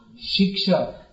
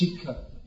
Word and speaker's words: shiksha: First, I have to tell you that in Bengali pronunciation, shiksha, which means instruction, is pronounced shikha.